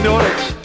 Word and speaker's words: Norwich: His Uncle Hamish moved to Norwich, no very Scottish, but it rhymes with porridge.